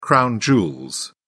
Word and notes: crown jewels: British English

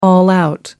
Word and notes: all out: American English

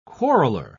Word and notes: quarreler: British English

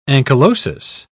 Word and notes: ankylosis: British English

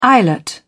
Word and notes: eyelet: British English

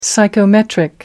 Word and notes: psychometric: American English

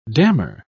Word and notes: damar: British English

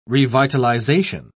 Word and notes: revitalisation: British English

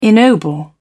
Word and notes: ennoble: British English

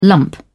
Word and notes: lump: British English

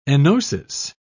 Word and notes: enosis: British English

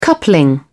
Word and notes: coupling: British English

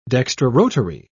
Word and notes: dextrorotary: British English

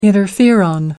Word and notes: interferon: American English

